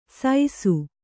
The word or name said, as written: ಸೈಸು